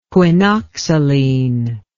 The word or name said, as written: quinoxaline